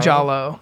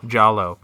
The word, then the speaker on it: giallo: These Gaio mysteries, is that how you pronounce it? – Gaio? Giallo. – Giallo.